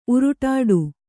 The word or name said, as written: ಉರುಡಾಟ